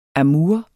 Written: amour

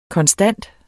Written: konstant

